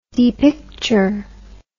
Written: depicturement

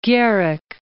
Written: Gierek

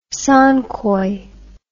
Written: Song Koi

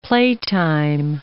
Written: playtime

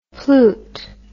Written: plute

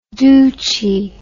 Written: douchie

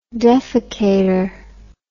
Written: defecator

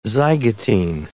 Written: zygotene

